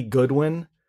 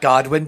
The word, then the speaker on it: Goodwin: His name is, I believe, Billy Goodwin. – It's not Godwin. It's not pronounced Godwin.